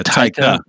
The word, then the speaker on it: Taika: Taika? – Yeah, Taika.